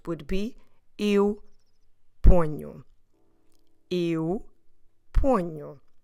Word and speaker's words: ponho: P-O-N-H-O. Ponho. And we have this nasal consonant sound nu because of the fact that we have the NH together. N-H, new. Also, that nasal sound, that nasal consonant sound, let's say it like that, also makes the first O slightly nasal too. So we start with pon, already nasal, poño, Don't forget to stress the second to last syllable in this case, the first one of the word.